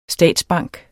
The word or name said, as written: statsbank